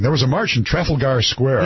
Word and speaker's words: Trafalgar Square: Trafalgar Square. – Trafalgar Square, yeah.